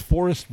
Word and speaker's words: 4EST: It's Forest Brewery. Like, that's what it is. But it's spelled with a 4 and an E-S-T.